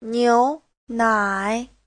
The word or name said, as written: niu2nai3